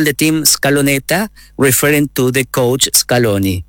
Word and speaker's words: Scaloneta: Did I say Scaloneta? Did I say that right? – Yeah.